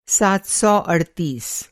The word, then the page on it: सात सौ अड़तीस: سات سو اڑتیس